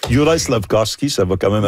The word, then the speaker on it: Juraj: Personnellement, moi, j'ai de la difficulté avec Jurav Slavkovski. – Juraj.